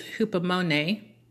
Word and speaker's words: hupomone: The Greek word translated as endured in James 5:11 is hupomone, and let me spell that for you, H-U-P-O-M-O-N-E. Again, that's H-U-P-O-M-O-N-E.